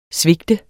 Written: svigte

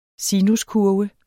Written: sinuskurve

